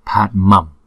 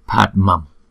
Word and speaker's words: Padmam: Thì chữ Anuswara trước đó đó, chúng ta cũng phải đọc bằng môi luôn, tức là đọc bằng M. Thì chữ này chúng ta đọc là Padmam, thấy không? Padmam, Padmam.